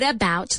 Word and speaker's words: about: What about the car? Cuidado con la pronunciación, la O en about se pronuncia como si fuera una A, what about, y se pronuncia todo junto, what about, what about, what about the car?